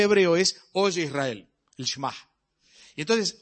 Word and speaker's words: Shema: Es notoria que la palabra que se utiliza allí Es la palabra que utilizan los hebreos en el Shema, así se pronuncia.